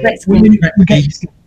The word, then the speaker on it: Gretzky: I can't say Gretzky.